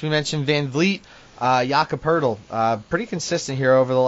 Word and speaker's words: Poeltl: This game could get ugly, so it could be Poeltl time. – Yeah, interesting way to pronounce his name. It's basically turtle with a P. – Yeah, Jakob Poeltl.